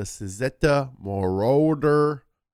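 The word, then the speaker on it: Cizeta Moroder: In 1991, a handful of ex-Lamborghini Machine employees, led by Claudio Zampoli, jumped into the pool and launched the Suzetta Moroder B16T. That's how it's pronounced.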